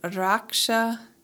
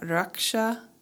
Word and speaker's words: Raksha: Today's mantra is Raksha Kali or Raksha Kali. It's spelled R-A-K-S-H-A. And I think you can either pronounce it Rak or Ruk.